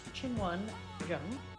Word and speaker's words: Qinwen Zheng: Her name is pronounced Chin Won Jung. Ch sound is how you pronounce a Q in Chinese, mm-hmm. And Jung is the J sound that accompanies a Z and an H.